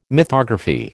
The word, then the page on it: mythography: American English